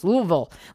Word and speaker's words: Louisville: And you got to say it like that. Like you got a mouth of marbles. Louisville. Like Louisville. There's no pronunciation there or something. It's very strange. And they will correct you if you go to Louisville and tell you that you overpronunciated it.